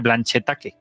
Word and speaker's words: Blanchetaque: No sé cómo se llama en francés, así en castellano se dice Blanchetaque, por si alguien lo quiere buscar. – A mí me gusta tu pronunciación, Blanchetaque, mola. – Blanchetaque, hay pronunciación.